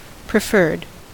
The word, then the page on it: preferred: American English